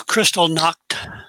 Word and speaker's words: Kristallnacht: Suddenly we had Kristallnacht. Am I pronouncing that right? – Yeah, pretty close. Kristallnacht.